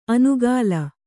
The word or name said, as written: ಅನುಕಾಲ